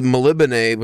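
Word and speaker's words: Melniboné: Well, I, like the Melibane, which is the city, I learned how to pronounce that, which I mean, it's kind, you know, it could be multiple things, but it's, you get close to the pronunciation by reading it, like phonetically, but I just looked up a couple YouTube videos and, you know, Elric of Melibane.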